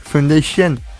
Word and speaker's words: Foundation: Alors, c'est pas Fondation, c'est... Je sais même pas comment on le prononce. Fondation, il y a un U. Entre Foundation.